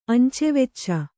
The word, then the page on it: ಅಂಚೆವೆಚ್ಚ: Kannada